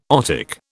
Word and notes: otic: British English

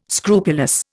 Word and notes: scrupulous: Indian

